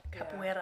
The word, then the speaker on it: capoeira: So capoeira. – Yeah, you have to pronounce it like capoeira.